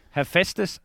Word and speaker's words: Hephaestus: Any danger you could pronounce the name of the horse he's driving? – Hephaestus Phoenix. – You've done very, very well. Well done.